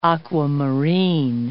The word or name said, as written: aquamarine